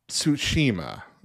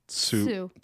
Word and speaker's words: Tsushima: Tsushima, I'm pretty sure is how it's pronounced. Starts with a T, but it's TS. So yeah. That's like Tsu.